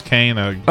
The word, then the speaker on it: Koenig: Is it Koenig? I think it's pronounced Koenig.